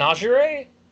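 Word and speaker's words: Augurey: An augury? Is that how do you say it? – Yes. Wow. Good call.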